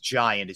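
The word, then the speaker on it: Geant: I think it's pronounced Giant, it's G-E-A-N-T, has okay.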